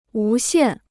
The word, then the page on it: 无限: wú xiàn